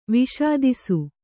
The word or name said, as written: ವಿಷಾದಿಸು